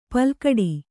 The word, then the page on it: ಪಲ್ಕಡಿ: palkaḍi